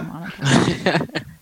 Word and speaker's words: Minotaur: minotaurs, or someone's going to, I think I just butchered that pronunciation, but I can't pronounce that.